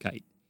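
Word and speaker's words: Keith: His name's Keitt, yeah. That's how it's pronounced. The German is spelled different. It's not spelled K-I-T-E. It's just, it's pronounced Keitt.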